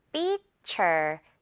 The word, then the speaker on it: feature: feature, T-U-R-E 부분, cher 발음.